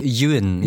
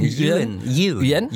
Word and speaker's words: Ewan: Und McGregor hat gesagt, übrigens auch ein Name, wo wir nochmal geguckt haben, wie wird er ausgesprochen. Wir sind uns unsicher. Ian, Ewan. – Ewan. – Ewan.